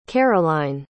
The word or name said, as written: caroline